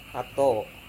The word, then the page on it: ato: Serewen